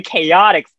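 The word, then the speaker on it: KOTX: And it's actually Chaotix because, you know, they're chaotic.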